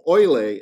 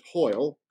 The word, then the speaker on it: Hoyle: And it was only until the 1930s and 40s that a really remarkable man named Rafael Larco Oile, is the Spanish pronunciation, we would pronounce it Hoyle, who was a hacienda owner in the Moche Valley.